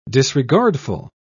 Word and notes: disregardful: British English

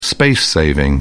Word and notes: space saving: British English

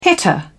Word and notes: hitter: British English

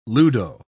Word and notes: ludo: British English

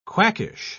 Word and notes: quackish: British English

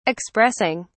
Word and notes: expressing: British English

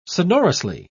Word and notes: sonorously: British English

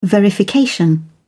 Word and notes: verification: American English

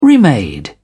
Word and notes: remade: British English

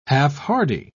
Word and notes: half-hardy: British English

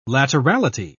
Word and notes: laterality: British English

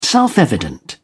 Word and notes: self-evident: British English

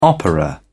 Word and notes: opera: British English

